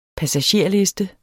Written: passagerliste

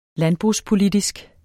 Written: landbrugspolitisk

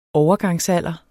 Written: overgangsalder

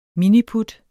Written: miniput

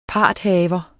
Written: parthaver

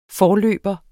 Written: forløber

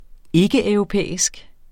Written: ikkeeuropæisk

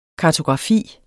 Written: kartografi

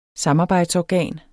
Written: samarbejdsorgan

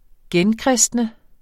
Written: genkristne

